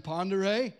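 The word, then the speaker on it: Pend Oreille: There's a lake there, and you don't know how to pronounce it, but it's actually Pondere, and it's spelled not like that at all.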